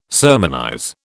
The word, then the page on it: sermonize: British English